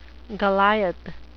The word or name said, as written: goliath